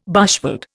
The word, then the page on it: bushveld: Indian